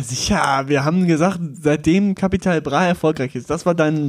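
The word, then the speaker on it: Capital Bra: Ja, es wird Kapital Bra ausgesprochen, aber ist auch nicht so schlimm.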